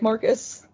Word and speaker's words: Marquise: I think they were pronouncing it like Marcus, but it's spelled like Marquise.